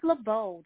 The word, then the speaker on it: Labeaud: It's Labeaud. The D is silent.